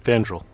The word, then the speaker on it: Fandral: We had the replacement of Fanfear. Did I pronounce that right? – Fandral.